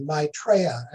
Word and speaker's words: Maitreya: My, M-A-I. – My. – Spelled, pronounced my, Treya, T-R-E. Y-A, Maitreya, accent on the second syllable.